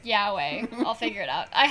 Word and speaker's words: yaoi: How do you say it? – Yaoi. – There you go.